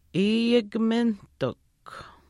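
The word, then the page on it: iiyikiminaahtikw: Chisasibi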